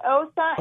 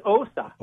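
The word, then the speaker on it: Åsa: Yes, that's Osa Well, you guys are awesome. Thanks for being here. This is incredible. – Yeah, you pronounce it more with an O. It's Osa.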